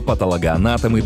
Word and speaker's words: патологоанатом: Двойные ударения для русского языка не очень характерны, но это слово – тот самый случай. Есть только один правильный способ его произносить – патологоанатом. Именно его рекомендует орфоэпический словарь русского языка как государственного. Обращаем внимание на ударное «о» после «т». Его часто заменяют на «а», но это неправильно.